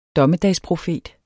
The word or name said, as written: DOMMEDAGSPROFETEN